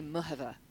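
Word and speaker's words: Muchová: Because apparently I've been criticised for The actual pronunciation, if you go onto the official website, and I was doing some announcing on Centre Court for these championships, and it is apparently Muhova. So you don't say K, you don't say the K.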